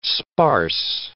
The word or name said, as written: sparse